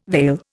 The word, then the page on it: vail: Indian